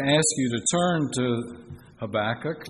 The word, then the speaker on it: Habakkuk: Now, I've only heard it ever once pronounced differently. I respect the lady. She's British. She's the wife of Stuart Briscoe, Jill Briscoe. She pronounces it Habakkuk. I'm going to stick with Habakkuk, but she could very well be right. That may be just how the British pronounce it.